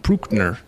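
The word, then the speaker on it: Brückner: I'm going to butcher the last name. Bruckner. – He's got an umlaut over the U. Bruckner.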